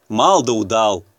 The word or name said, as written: мал да удал